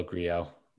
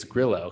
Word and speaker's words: Grillo: Grillo, Griot. Nobody knows how to say his name. – I bet it's Griot. – It is Griot. He's said publicly it's Grillo, because I think he just wants people to, you know, he's like, okay, I'm going to Americanize it, and I just don't want people to just butchering it.